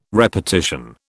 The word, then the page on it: repartition: British English